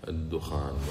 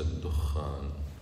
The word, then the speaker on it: الدخان: صورة الدخان طبعا هكذا تنطق بالتخفيف، الدخان وليس الدخان، صورة الدخان.